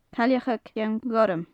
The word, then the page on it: cailleag cheann ghorm: gaelic